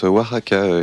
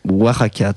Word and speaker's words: Oaxaca: À l'autre bout de la planète, on a à Oaxaca. – On prononce Oaxaca. – Oaxaca, très bien.